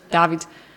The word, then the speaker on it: David: Genau, du betonst es anders. Ich sage nämlich, ich habe die Betonung auf dem A, glaube ich, nämlich David. Und nicht David.